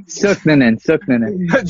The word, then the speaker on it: Sooknanan: Sooknanan.